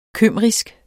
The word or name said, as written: kymrisk